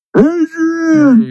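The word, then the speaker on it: Adrián: Adrian. – You gotta say it right with your lip twisted. – Adrian!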